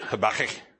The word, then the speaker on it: Habakkuk: If you can find Habakkuk, I have to say it like that, Habakkuk.